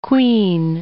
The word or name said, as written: quean